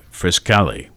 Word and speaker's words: Frascati: Frascati, I think, is how it's pronounced, the community in Italy. Not Frascati, Frascati. There we go.